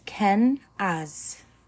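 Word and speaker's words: Kenaz: Uh, pronunciation is ken as.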